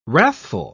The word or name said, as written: wrathful